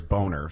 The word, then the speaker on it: Boehner: The official pronunciation of Boehner is Boner, so whatever.